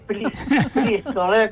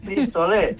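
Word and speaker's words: FlixOlé: A ver, pronúncialo bien. – Frisolé con X, Frisolé. – Sí, como los colchones Flex, pero Flix.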